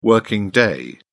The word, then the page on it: working day: British English